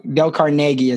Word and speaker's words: Carnegie: Dale Carnegie is, I think, actually how you say it.